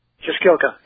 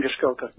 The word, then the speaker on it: Jaskilka: How do you say that last name, Jaskilka? – Jaskilka, yeah, Sam Jaskilka, yeah.